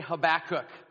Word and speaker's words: Habakkuk: Everybody say Habakkuk. Habakkuk. Some people pronounce it Habakkuk. I've heard it pronounced with emphasis a little bit later in the word there.